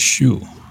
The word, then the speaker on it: Xu: So when they My last name pronounced Xu, but imagine Boston Lockjaw pronounced that. That's where the Z came from. What's your name? Not Z. Like Xi Jinping, his name is Xi. Xi. Xi. It's different. Ours is Xu, but just because of the lockjaw thing.